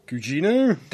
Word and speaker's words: Gugino: Gugino? – That sounds correct.